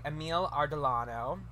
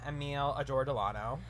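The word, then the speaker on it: Emile Ardolino: Yes, it was written by Eleanor Bergstein and produced by Linda Gottlieb and directed by Emil Ardolano, who I didn't know how to pronounce his name for the longest time and was calling him Emil Adordolano.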